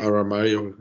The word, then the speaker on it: Aramayo: Aber ähm, apropos Elfen, ähm, ich muss auch sagen, dass unser Elrond, also Robert Aramayo, hoffentlich richtig ausgesprochen, Aramayo, gefällt, Aramayo, ja.